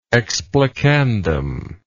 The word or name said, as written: explicandum